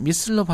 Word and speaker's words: missile: 미사일은 미슬로 발음하죠? – Astronaut Hall of Fame.